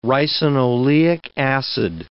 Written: ricinoleic acid

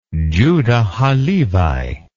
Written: Judah ha-Levi

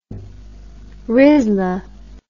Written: rizla

